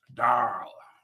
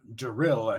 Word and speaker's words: Darl: Or pronounced, sorry. – Darl. – So we can have, like, Walking Dead Darl memes. – Or you can call him Daryl at your risk.